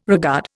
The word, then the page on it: regard: Indian